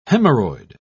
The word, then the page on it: hemorrhoid: British English